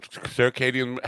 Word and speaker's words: circadian: Circadian rhythm, circadian, how do you pronounce that and what is it? – Yeah. Circadian. Right. So imagine it's like a medieval night, circadian. And okay. not cicada nor curcumin.